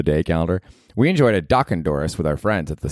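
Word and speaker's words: dochandorris: Dude, my word of the day on Saturday was Doc Ondoris, which is Scottish and Irish, and it means a parting drink. It's spelled D-O-C-H-A-N-Doris, D-O-R-R-I-S, Doc Ondoris.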